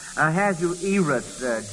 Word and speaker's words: Ahasuerus: Ahasuerus. – Yes. I think it's pronounced Ahasuerus.